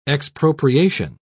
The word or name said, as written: expropriation